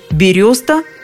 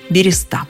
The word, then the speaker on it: береста: Орфоэпический словарь под редакцией Аванесова, например, приводит вариант «береста» как основной, но тут же упоминает и возможный допустимый вариант «береста». Толковый словарь русского языка под редакцией Шведовой считает равноправными оба варианта. Такие же рекомендации вы встретите в словаре ударений Резниченко. Береста, береста, выбирайте любой из двух вариантов.